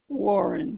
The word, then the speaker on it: Warren: First name is all one name, Rose Marie, R-O-S-E-M-A-R-I-E. Initial K, Warren, W-A-R-R-E-N.